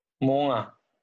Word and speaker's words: Mo'unga: You could at least pronounce his name right. – Mo'unga.